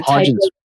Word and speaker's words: Hodgkin's: It's a Hodgkin's.